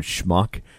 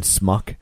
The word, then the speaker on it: schmuck: Like there's a funny scene in the movie where like Dustin Hoffman is trying to teach Warren Beatty how to say the word schmuck, because he keeps saying smuck.